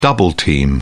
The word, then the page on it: double-team: British English